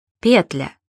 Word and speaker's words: петля: К ним относятся, например, такие слова. Благо. Благом, благами, о благах. Петля. Петлю, петлей, о петле. Договор. Договора, о договоре.